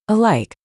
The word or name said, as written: Alike